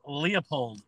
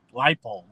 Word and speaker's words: Leipold: This was I'm pretty sure a Paul Christ and Lance Leopold scheduled game. Leipold, whatever his name is. – Yeah, it's pronounced both ways.